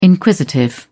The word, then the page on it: inquisitive: British English